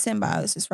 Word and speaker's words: symbiosis: I know I pronounced symbiosis right. – You did. You crushed it.